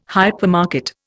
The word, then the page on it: hypermarket: Indian